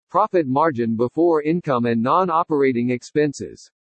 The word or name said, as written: PROFIT MARGIN BEFORE INCOME AND NON-OPERATING EXPENSES